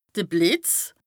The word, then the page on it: de Blìtz: Bas-Rhin; Strasbourg